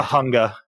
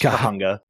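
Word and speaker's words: Cahuenga: Kahunga. It's pronounced Kahunga.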